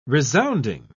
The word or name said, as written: resounding